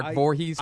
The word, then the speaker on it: Voorhees: Am I saying her name right? Voorhees? – As far as we know, you're correct.